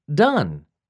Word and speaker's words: done: Do, did, done. Do rhymes with two and blue. Did rhymes with hid and sid. Done rhymes with sun and fun.